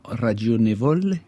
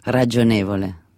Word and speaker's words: ragionevole: Par exemple, vous pouvez m'apprendre à prononcer correctement ragionevole? – Ragionevole.